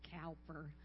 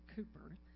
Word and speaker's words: Cowper: And so I looked it up, and it's written by a man named William, and a good Texan would say Cowper, because it's spelled C-O-W-P-E-R, but the English apparently pronounced it Cooper.